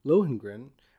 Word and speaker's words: Lohengrin: called Lohengrin. Is that how you pronounce it? – That's Lohengrin. How I would pronounce it.